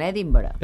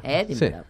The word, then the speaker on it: Edinburgh: Atenció a Edimburg, perquè si ho pronunciem com ho pronuncien ells, ells diuen Edinburgh. – Edinburgh. – Però, en canvi, acaba en Burg.